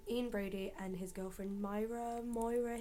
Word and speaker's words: Myra: It's spelled Myra, but everyone I've spoken to says like Moira. – Moira.